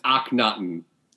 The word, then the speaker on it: Akhenaten: It's Aten and it's Akhenaten. – It just says A-T-E-N.